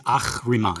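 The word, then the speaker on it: Ahriman: It's Achriman. – Achriman, thank you. Or Ach, like a cat hiss. Achriman.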